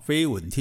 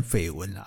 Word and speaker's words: 绯闻: 注意哈，这个字要念作“ 飞” 哈，飞蚊听起来好像得飞蚊症哈，那可是我们都习惯念成绯闻啦，其实它本来也是飞蚊哈，反正不管绯闻还是飞蚊哦，他们都觉得这是别人家的事情，跟我们没有关系。